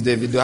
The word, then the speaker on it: Davido: your apostle came with it, he's telling me it's Davido.